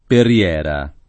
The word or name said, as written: Perriera